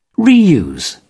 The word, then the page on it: reuse: British English